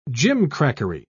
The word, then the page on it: gimcrackery: British English